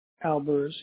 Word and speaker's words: Albers: And then, Arthur, your last name is Albert? – Albers, yeah. – Albert. How do you spell that? – A-L-B-E-R-S. – E-R-S, okay.